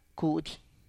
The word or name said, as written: kudy